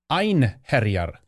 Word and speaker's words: Einherjar: I realize I mispronounced Futhark's term there. Einherjar. That's what it's. That's the term, which is on Veterans Day.